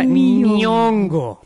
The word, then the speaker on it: Nyong'o: Oye, pero además es imposible pronunciarlo como si no fuera ñ, pero no es ñ, es ny. – ¡Ny! – ¡Nyong'o! ¡Nyong'o! – O sea, Nyong'o.